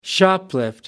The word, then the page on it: shoplift: British English